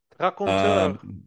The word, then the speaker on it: Raconteur: So, it's called the Raconteur, and Raconteur, I don't know how you pronounce that in English, but I pronounce it in French. – Raconteur.